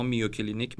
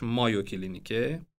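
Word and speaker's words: Mayo Clinic: ما میو کلینیک می نویسیم ولی تلفظ درستش مایو کلینیکه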